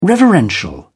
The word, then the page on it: reverential: British English